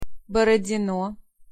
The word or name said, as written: Borodino